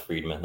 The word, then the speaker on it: Fridman: Lex, is it Friedman? – I think it's pronounced Friedman, Lex Friedman. – Okay, it's Friedman, but he doesn't include the E on it for some reason.